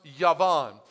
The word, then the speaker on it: Javan: It's spelled J-A-V-A-N, but it's pronounced Yavan.